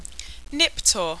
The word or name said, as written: Niptor